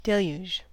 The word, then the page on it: deluge: American English